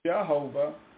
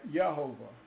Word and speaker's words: Yehovah: Now, they pronounce it with a J, Jehovah, but there's no J sound in the Hebrew, okay? So it was impossible for it to be Jehovah. But they spell it right, Yehovah, all right?